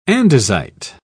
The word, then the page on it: andesite: British English